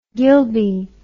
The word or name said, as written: gilbey